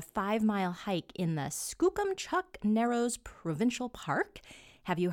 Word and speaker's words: Skookumchuck: So I'm really happy to hear how it's pronounced correctly. Yeah, Skookumchuck, it's Salish for fast waters, and it's about okay.